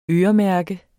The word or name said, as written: øremærke